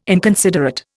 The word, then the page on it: inconsiderate: Indian